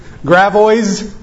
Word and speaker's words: Gravois: Like if I were driving into Arnold, Missouri this evening and I had seen on the sign Gravois Road, but I didn't know how to say that, how would I probably say it? How have you heard it said? Gravois? See, it's another one of those French words where the S isn't pronounced.